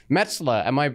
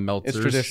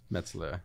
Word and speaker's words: Metzler: I get a lot of Meltzers, so I really appreciate that you actually pronounced it Metzler. – Metzler. – Like New York Mets.